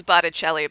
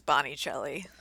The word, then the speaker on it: Botticelli: Yeah, she means Botticelli, but she says Bonicelli, which is hilarious.